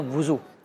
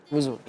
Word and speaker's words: وضو: وضو ہی اکثر نکلتے ہیں تو لیکن صحیح تلفظ ہے واو پر پیش ہے جسے دمہ کہتے ہیں وضو وضو